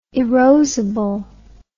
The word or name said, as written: erosible